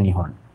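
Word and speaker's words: Nihon (日本): Nihon itu Nippon itu loh. Cara bacanya Nihon.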